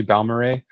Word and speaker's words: Balmorhea: It's actually Balmorhea, which you would never, but it's like they really just phone in the pronunciation on a lot of words.